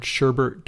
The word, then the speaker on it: sherbet: that the word sherbet doesn't have an R in it. – Oh, you Americans. So many Americans pronounce it sherbet. – Yes. How do you say it? – Sherbet. Like as if it's kind of like a U or an I. Like S-H-E-R-B.